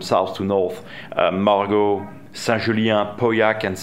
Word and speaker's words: Pauillac: Now that is the Pauillac. Did I pronounce it? – Pauillac region. – Yeah, exactly.